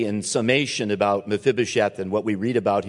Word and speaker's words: Mephibosheth: Mephibosheth. Mephibosheth.